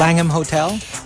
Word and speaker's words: Langham: Am I saying that right? Langham Hotel? – Langham Hotel.